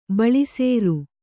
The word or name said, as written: ಬಳಿಸಾರ್